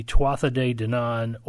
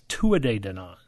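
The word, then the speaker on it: Tuatha Dé Danann: The Tufa are descended from an exiled band of Celtic fairy folk, which are called the Tuatha De Danann, or actually I think it's Tua De Danann. It comes out sounding like Tua De, like football practice, if you really get it in the right pronunciation.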